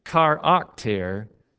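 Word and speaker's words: charakter (χαρακτήρ): And if I could show you this word in English words, translated into English words, you'd see immediately when I say charakter, you don't immediately see it because of the pronunciation, but it is spelled in English exactly like the word character, except in Greek it would be a K instead of a C.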